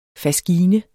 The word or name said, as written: faskine